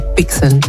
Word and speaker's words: BNXN: Oh, in case you didn't realise, that was Mood by by Wizkid featuring Bixen. And that's spelled B-N-X-N.